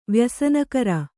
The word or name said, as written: ವ್ಯಸನಕರ